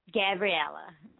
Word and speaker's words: Gabriela: Gabriela.